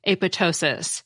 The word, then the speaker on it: apoptosis: The pronunciation without the second P, apoptosis, seems to be what people used in 1972 when biologists coined the word.